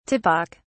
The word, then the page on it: typic: British English